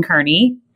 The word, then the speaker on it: Kearney: His name is Quentin Kearney.